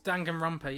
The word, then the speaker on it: Danganronpa: Danganronpa? – Yeah, he streamed Danganronpa, yeah.